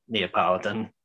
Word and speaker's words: Neapolitan: Then they also did a Nepletan. Is that how you pronounce it? – Nepletan. – Nepletan. – That's how I just say Italian. It's just Neapolitan, if you wanted to say it.